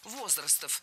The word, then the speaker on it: возрастов: Было сказано «возрастов», но это неверно. Ударение в нашем слове должно падать на первый слог «возрастов».